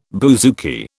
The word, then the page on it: bouzouki: British English